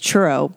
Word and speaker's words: churro: That's what you're saying it wrong. It's just churro, but right.